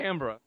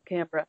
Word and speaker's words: Canberra: It, they everyone laughs who's from there because they're like, okay, no one pronounces this right. Um, but Canberra. Yep, Canberra.